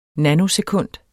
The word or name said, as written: nanosekund